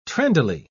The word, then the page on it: trendily: British English